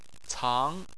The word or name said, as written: cang2